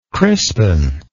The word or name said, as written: crispen